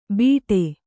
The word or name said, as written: ಬಿಟೆ